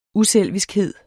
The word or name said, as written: uselviskhed